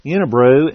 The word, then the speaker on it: Unibroue: Unibrew, and this is spelled Unibroue. That's the, I suppose, French pronunciation, or in the U.S. the way we call it, the wrong way to spell Unibrew.